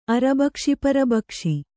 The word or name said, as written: ಅರಬಕ್ಷಿಪರಬಕ್ಷಿ